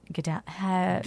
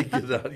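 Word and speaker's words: Gedaliah: Gedalia, how do you pronounce that? Gedalia. – Yeah, Gedalia. Yeah.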